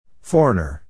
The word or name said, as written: foreigner